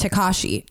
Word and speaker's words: Tekashi: It's Tekashi.